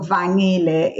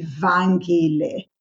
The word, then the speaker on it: Vangile: So the NG in Siswati is pronounced as a ni, like singing. So Vangile in Siswati. But if I was Xhosa, my name would be Vangile, which in Xhosa, this is this, it's also a name that is there, but the G is harder.